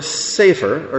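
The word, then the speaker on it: sefer (ספר): You could pronounce that word sefer or sefer, and if you do, it means book. A Torah scroll is called a sefer Torah, a book of Torah, a scroll of Torah. But if you change the pronunciation, not to change the spelling, just change the pronunciation to sofer, it means a scribe, one who makes Torah scrolls and who writes books, a sofer.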